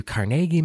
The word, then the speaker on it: Carnegie: So but for the U.S., they're supporting their Scottish heritage because it's founded by Andrew Carnegie, which is how they say it there. If you go to Pittsburgh, they'll say, "Welcome to Carnegie Mellon." And you're like, what was it? Yeah. – Really? – Yeah. – It is actually, I've heard a lot of people say that. And yeah. I'm just like, why are you saying it's Carnegie? – That? – Yeah. Because that's how, apparently, that's how he liked his name pronounced, being very Scottish.